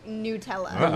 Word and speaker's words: Nutella: They have a pronunciation guide on their website. It says it's pronounced Nutella.